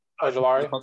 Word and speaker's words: Ojulari: How you pronounce his name? Ojulari? – Ojulari. – I said it right? – Yeah.